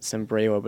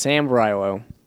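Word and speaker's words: Sambrailo: We've heard some people erroneously mispronounce it, Sambrylo, but it's Sambrylo.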